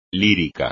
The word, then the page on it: lírico: Spanish